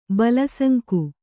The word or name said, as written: ಬಲಸಂಕು